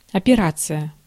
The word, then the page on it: operation: American English